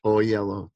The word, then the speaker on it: Oyelowo: Oyelowo, thank you.